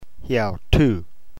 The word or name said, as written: heautou